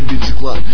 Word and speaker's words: Биджи Клан: Название команды — это Биджи Клан. Произносится как G, понял, баран?